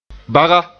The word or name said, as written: baga